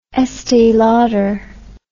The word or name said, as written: estee lauder